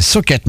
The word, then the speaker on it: Sochat: Ça s'écrit S-O-C-H-A-T. On pourrait prononcer Socha. C'est Socket.